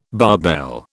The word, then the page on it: barbell: British English